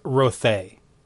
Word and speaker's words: Rothay: So, for the record, the official pronunciation is Rothay. – Oh, because it's based on Dutch.